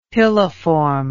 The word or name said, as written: piliform